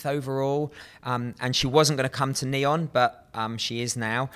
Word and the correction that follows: NEOM: I love how you call it Neon. – You're putting an N on the end of that? – Yeah, you definitely are. – How do you say it? Why is it always in capitals as well? Everywhere N-E-O-M.